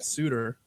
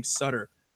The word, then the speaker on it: Suter: You know, I spent a lot of time going through, and by the way, liked the correct pronunciation on Brent Suter. I had to look up some starts from him and to get the pronunciation because I'd been saying Sutter.